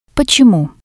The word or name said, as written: potsemu